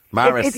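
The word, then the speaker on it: Maris Piper: Yeah, I'm there now, they're giving out to me, but somebody's in my ear here giving out to me about Mars Pipers. It's Mars Pipers. Maris.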